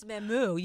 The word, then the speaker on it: Mamu: It's Mamu. You emphasis on the moo.